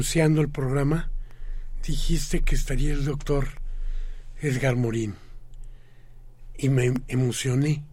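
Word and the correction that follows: Morin: Por Edgar Morán. Por, Sí, pronunciado en francés, pero escrito igualito.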